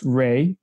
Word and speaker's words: Reay: You can find my Facebook profile, Ed Ray. So my first name is Ed, last name R-E-A-Y. Yes, it's pronounced Ray, but R-E-A-Y.